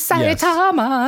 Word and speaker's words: Saitama: It is Saitama.